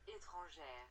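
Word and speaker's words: Étranger: And we are here to talk to you about Act 14, Conclusion and Commencement, Petit Etranger, or if you want to hear like the actual pronunciation of it, Etranger. There you go. You could hear it twice because I accidentally hit it twice.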